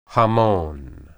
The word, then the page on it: hamon: Hebrew